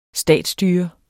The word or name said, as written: statsstyre